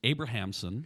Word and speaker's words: Abrahamson: and that is that our Justice Shirley Abramson is Abrahamson. – Abrahamson. – I got to get that pronounced right.